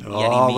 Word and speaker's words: Yerry Mina: Everton are still making other moves in the transfer market, and two Barcelona players in particular, Lucas Digne and Yerry Mina.